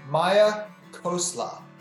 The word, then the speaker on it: Maya Khosla: For this episode, our guest is Maya Khosla.